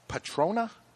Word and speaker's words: Patrona: La P A T R O N A. Please don't spell it to me. I got to read it. Patrona?